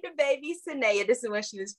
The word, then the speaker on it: Sanaa: And they heard Sanaa say her name is actually Saneya Lathan, but she lets people call her Sanaa because everybody just messes it up.